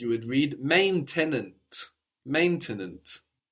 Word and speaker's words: maintenant: can visualize that, divide it up into its syllables, maintenant, and think about what you would and what you wouldn't pronounce in French, and give it a go, see what happens.